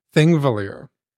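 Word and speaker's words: Þingvellir: Thingvellir.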